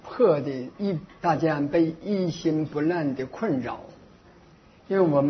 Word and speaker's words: 一心不乱: 他说我这个发音有错误啊，我在这里说明一下呢，那个一心不乱，那个我这个发音是不标准的，我再念一遍，一心不乱啊。